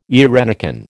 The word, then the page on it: eirenicon: American English